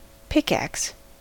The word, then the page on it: pickaxe: American English